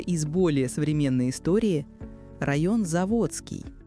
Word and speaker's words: Заводский: Все же кеменовчане знают, что ударение ставится на букву «О». А знаете почему? Название крупнейшего района города – происходит от старого сибирского выражения «за водой». Для жителей правобережной деревни Кемерово эта территория находилась за водой, за Томью. Поэтому исторически ударение ставилось на «о» – «заводский», а не «заводской».